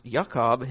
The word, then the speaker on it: Yaakov: But the word comes from Akab, which means heel catcher, and it's pronounced Yaakov, his name.